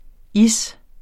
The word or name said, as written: is